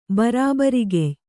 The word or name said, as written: ಬರಾಬರಿಗೆಯ್